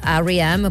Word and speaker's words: R.E.M.: abbiamo sempre chiamato REM, in realtà R.E.M.